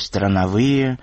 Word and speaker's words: страновые: Не изменилось положение дел и после того, как в Абхазии с 2012 года стали проводиться страновые, ударение тут на предпоследнем слоге, конференции.